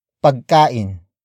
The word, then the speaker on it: pagkain: Walang wala, akong, pagkain.